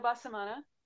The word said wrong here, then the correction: Bonamassa: Oh, Joe Bonamassa.